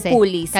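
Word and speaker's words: calpulli: Calpullis, me parece. No se pronuncia con la sh.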